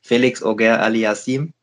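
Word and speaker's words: Félix Auger-Aliassime: Ähm, hat leider eine schwere Auslosung bekommen gegen, ähm, ich spreche ihn heute mal richtig aus. Ich weiß nicht, ob du das Video schon gesehen hast. Bei der Auslosung heute wurde er komplett falsch ausgesprochen. Felix Auger alias Sim in der ersten Runde.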